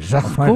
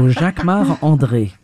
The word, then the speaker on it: Jacquemart-André: You say it like André. – A Frenchman. – Jacquemart-André. Of course, because I'm French. So Jacquemart-André. – And say it so we can understand it. – Jacquemart-André.